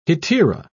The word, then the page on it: hetaera: British English